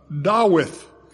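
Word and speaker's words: Dawith (דָּוִד): And when the psalm says, a psalm of David, it says le, Lameth with a short E on it, le Dawith, which is how you pronounce David's name in Hebrew, le Dawith.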